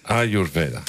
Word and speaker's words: Ayurveda: Ayurveda. – Ayurveda.